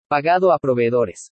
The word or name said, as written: PAGADO A PROVEEDORES